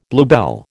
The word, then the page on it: bluebell: American English